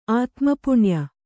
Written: ಆತ್ಮಪುಣ್ಯ

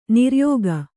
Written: ನಿರ್ಯೋಗ

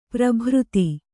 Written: ಪ್ರಭೃತಿ

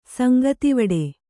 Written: ಸಂಗತಿವಡೆ

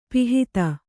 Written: ಪಿಹಿತ

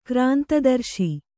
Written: ಕ್ರಾಂತದರ್ಶಿ

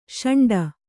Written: ಶುಂಠಿ

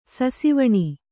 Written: ಸಸಿವಣಿ

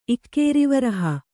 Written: ಇಕ್ಕೇರಿವರಹ

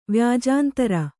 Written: ವ್ಯಾಜಾಂತರ

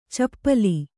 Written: ಚಪಲೆ

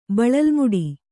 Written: ಬಳಲುಮುಡಿ

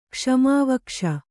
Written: ಕ್ಷ್ಮಾವಕ್ಷ

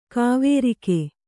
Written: ಕವರಗಿ

